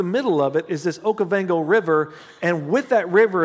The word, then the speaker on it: Okavango: Now, there's, there's a lot of cool stuff out there, but particularly these lions in Africa, and they are the, the Okavango, Okavango, I think is how you say it, Okavango.